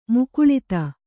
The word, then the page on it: ಮುಕುಲಿತ: kannada; mukulita